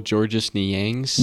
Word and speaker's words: Georges Niang: But I love Georges Niang. He had some great, played some great basketball here. – Honestly, I feel like the Jazz have gone through multiple Georges Niangs.